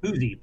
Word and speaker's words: Poosey: Pusey, P-O-O-S-E-Y, Pusey Conservation Area.